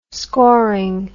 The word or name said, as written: scoring